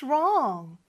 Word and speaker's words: wrong: 자, 물론 W-R-O-N-G이지만 W는 소리가 이렇게 나지 않고 R 소리가 납니다.